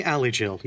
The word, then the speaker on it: Alejeal: Rhamy Alejeal.